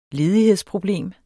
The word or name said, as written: ledighedsproblem